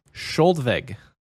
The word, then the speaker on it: Skjoldvegg: The name we're about to say is Skjoldvig. Skjoldvig. – Skjoldvig? – Skjoldvig. It's Skjoldvig? Spelled, again, it's spelled S-K-J-O-L-D-V-E-G-G.